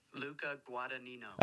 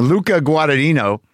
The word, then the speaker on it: Luca Guadagnino: Luca Guadagnino. – I did it. It's Brendan. Brendan's sending me the pronunciation. Luca Guadagnino.